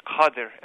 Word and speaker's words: Qadir: There's no doubt that Rabia Khanim, her name in Uyghur is pronounced Rabia Qadir, an unfortunate side effect of the way that Uyghur names are transcribed into Chinese and then brought into English, that people know her as Kadir.